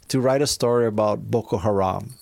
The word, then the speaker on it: Boko Haram: First of all, am I pronouncing it right? – Because you are, yes. – Boko Haram? – Mm-hmm. Boko Haram, okay.